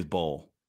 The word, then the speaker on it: Bol: Is it Bobo? Is that how you pronounce it? – I don't know. Manute Bol. His last name is Bol. – Yeah, and his son took his last name and said it twice.